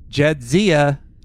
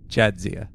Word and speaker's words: Jadzia: Says, it's Jadzia, not Jadzia.